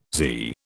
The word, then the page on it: zee: British English